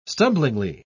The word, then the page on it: stumblingly: British English